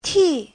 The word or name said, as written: ti4